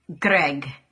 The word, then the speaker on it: Greg: Greg. – Okay. – But that's the Scottish accent. If you're from the US, it's Greg.